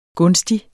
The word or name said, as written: gunstig